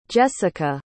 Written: Jesica

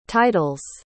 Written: titles

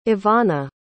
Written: ivanna

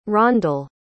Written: rondal

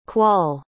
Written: quoll

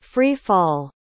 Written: free-fall

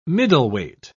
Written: middleweight

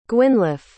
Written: Gwynllyw

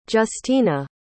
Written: giustina

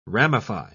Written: ramify

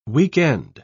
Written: weekend

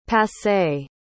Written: passé

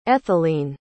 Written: ethelene

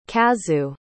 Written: kazu

